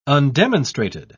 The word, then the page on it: undemonstrated: British English